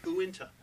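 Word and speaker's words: Uintah: How do you say it? – Winta. – Winta. You sure? – Yeah.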